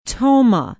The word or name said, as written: tomah